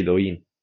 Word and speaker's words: Douyin: porque TikTok en China es Doujin, se pronuncia Doujin, ¿verdad? – Sí, Douyin.